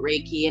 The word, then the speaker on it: Reiki: So Reiki.